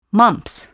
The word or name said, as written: mumps